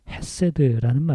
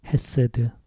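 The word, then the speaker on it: 헤세드 (חסד): 여기서 인자하심이라는 말은 히브리 말의 헤세드라는 말입니다. 이 단어는 꼭 기억세포에 넣어두시기 바랍니다. 헤세드. 헤세드. 그게 히브리 발음인데요.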